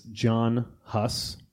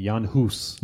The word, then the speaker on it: Jan Hus: Okay, now in the Czechoslovakian, he's Czech. – Right. – And you would pronounce his name Jan Hus. – Jan Hus. – Jan Hus. But I'm going to say John Hus because I'm English, because I'm American.